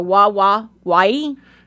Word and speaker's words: Wawawai: No, it's W-A. So Wawawai. – Yeah, W-A-W-A-W-A-I, Wawawai. – Okay. – Yeah. – Wawawai. Yeah. – It's Elmer Fudd's park. Wawawai.